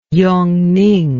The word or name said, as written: yongning